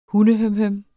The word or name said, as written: hundehømhøm